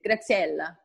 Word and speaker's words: Graziella: But every time I just say like, pretend that you speak Italian. Just do it. Graziella. That's it.